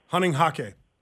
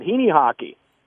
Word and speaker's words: Hunninghake: Hunting hockey. – Heaney hockey? Oh, they're a bunch of crazy Germans. – Heaney hockey. How you pronounce it? – Heaney hockey. – That's how they pronounce it, Heaney hockey.